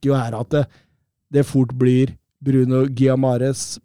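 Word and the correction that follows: Guimarães: Giamares, åssen uttaler det? Giamares. – Gimares. Gimares. Gimares. Det blir Gimares mm.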